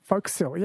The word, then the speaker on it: forecastle: Foc'sle, yes.